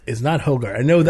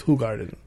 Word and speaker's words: Hoegaarden: Because it's not Hoegaarden. I know that for a fact. It's not Hoegaarden. It's Hoegaarden.